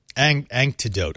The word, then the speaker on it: anecdote: Oh, I do want to move on, but I'll leave you with a little antidote. Anecdote. I can never say that properly.